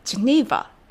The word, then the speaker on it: Geneva: ジェニーバはい、スイスのジュネーブは英語でジュニーバと発音します。